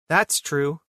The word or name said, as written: That's true